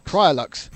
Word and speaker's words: Priaulx: It's commentators trying to pronounce Andy Prio's name not knowing what it is. – Priolux. – Hey, it's Andy Priolux.